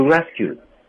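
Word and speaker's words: rescued: 我演绎之前呢，告诉大家一点：You rescue，刚才这个晶晶说的，You rescue， D 失去爆破的不发音，只有口型。 You rescue me，You rescue me。你要是把 D 发了音，你这个语言表达就力量就破掉了，所以 D 不可以发音，只有口型存在。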